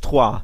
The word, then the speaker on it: Troyes: Genau, du hast, ich habe Troyes gesagt, das war im Rasenfunk nämlich, und dann kam auch Leserzuschrift, und nee, nicht Troyes, das heißt Troyes.